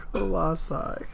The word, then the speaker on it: Colossi: Yeah, that's how you say it, right? Colossi? – Colossi. – You can't say Colossuses.